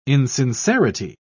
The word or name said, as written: insincerity